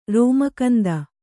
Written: ರೋಮಕಂದ

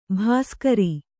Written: ಭಾಸ್ಕರಿ

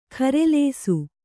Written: ಖರೆಲೇಸು

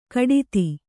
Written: ಘಟಿತ

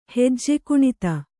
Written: ಹೆಜ್ಜೆಕುಣಿತ

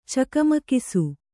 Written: ಚಕಮಕಿಸು

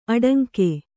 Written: ಅಡಂಕೆ

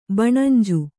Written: ಬಣಂಜ

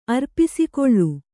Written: ಅರ್ಪಿಸಿಕೊಳ್ಳು

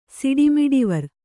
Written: ಸಿಡಿಮಿಡಿವರ್

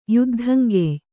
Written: ಯುದ್ಧಂಗೆಯ್

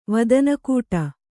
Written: ವದನಕೂಟ

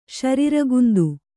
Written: ಶರಿರಗುಂದು

